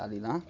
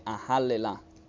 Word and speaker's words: Ahallela: Questa è un'altra parola che sembra Alila. – Ah, non è Alila? – No, nell'accento degli ebrei libici questo è Ahallela.